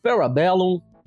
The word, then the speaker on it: Parabellum: É, Parabellum. Né? Acertei na pronúncia aí, é seu Matheus? – Acertou, claro que sim.